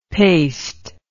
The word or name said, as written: paced